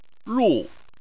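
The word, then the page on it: ru: tone 4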